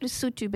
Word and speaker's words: Lesotho: So it's pronounced Lesotho but spelt Lesotho.